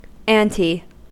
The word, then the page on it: ante: American English